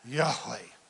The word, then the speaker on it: Yahweh: How do you say that? Yahweh.